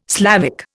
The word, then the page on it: Slavic: Indian